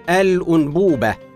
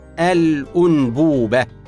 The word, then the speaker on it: الأنبوبة: من الكلمات المنزليه والمعمليه المستعمله بكثره كلمه الانبوبه هكذا تنطق الانبوبه بضم الهمزه ولكن البعض ينطقها بغير ذلك وهذا خطا شائع والصواب ضم الهمزه الانبوبه